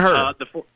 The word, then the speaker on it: Huerter: They have Kevin, I always mispronounce his name, Huerter. – Kevin Huerter. – Thank you, Huerter. Appreciate that.